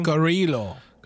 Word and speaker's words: Carrillo: So it's Ernesto Perez Criollo. I'm sure I'm saying that wrong. – Carrillo.